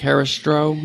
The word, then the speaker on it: Herăstrău: Lake Herăstrău. Am I saying it correctly? – Yep.